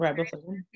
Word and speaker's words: riboflavin: Am I saying that right? – Mm-hmm. Riboflavin.